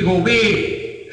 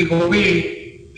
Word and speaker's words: Gobee: y Enrique Gobé, G-O-B-E-E, con acento en la segunda E, Enrique Gobé,